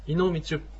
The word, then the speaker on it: イノミチュプ: で、このイノミチュプというのは、ちょっと発音、チュプというのは発音しづらいですが、練習してみましょう。 – はい。 – イノミチュプ。 – イノミチュプ。